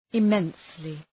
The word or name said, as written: immensely